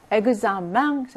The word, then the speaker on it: examen: Examen, 이거는 맹 발음이 나죠. 앵 발음이 납니다.